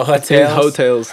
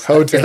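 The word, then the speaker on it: Heaux Tales: And then 10, I'm going to pronounce, is this French too? Hutales? What is Jasmine Solon? – Oh, Hotels. – Hotels. – They just her tales, but her in French is her.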